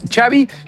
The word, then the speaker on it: Xavi: ¿cómo se pronuncia tu nombre? Y él dijo, Xavi como chocolate.